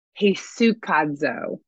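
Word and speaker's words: hesychazo: Okay, so quiet, this verb in the Greek is, I'm not going to, hesukazo, that's how you say it.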